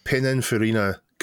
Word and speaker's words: Pininfarina: So this particular car is a Pininfarina conversion. Am I pronouncing that right? Chris? I think so.